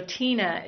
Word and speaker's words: Teena: But the trickier part is my first name, because my parents were creative, and so Tina is spelled T-E-E-N-A. So if you Google Tina Spindler, you'll see this herb pot video on YouTube.